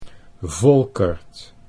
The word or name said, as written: Volkert